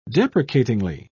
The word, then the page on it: deprecatingly: British English